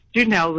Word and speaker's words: Janelle: Is it Janelle with a hard J or Janelle with a... – No, it's Janelle with a hard J.